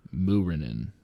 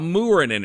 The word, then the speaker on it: Muurinen: Capital M-E-E-K dash K-A-H. Mika. – Muurinen. – Here's how Muurinen is spelled. Capital M-O-O, as in moo, like a cow, dash R-E-H dash N-E-N.